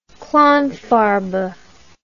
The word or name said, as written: klangfarbe